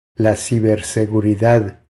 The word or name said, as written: La ciberseguridad